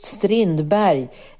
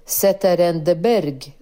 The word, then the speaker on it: Strindberg: Strindberg, eller Zetterberg som han uttalade.